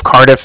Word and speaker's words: Cardiff: And then the Bank of Cardiff is spelled C-A-R. D-I-F-F, as in Frank Frank, so it's like car and then diff.